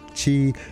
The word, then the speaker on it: Qi: Qi is pronounced C-H-E-E or C-H-I and is usually translated to mean life force or vital energy that flows through all things in the universe.